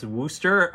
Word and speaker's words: Worcester: And I, as a New Englander, stubbornly insist on saying Worcester. But I will say that once I visited the New Echota Historic Site and the people told me that it is in fact pronounced Worcester, I'm going to continue to pronounce it Worcester until the day I die, I suspect.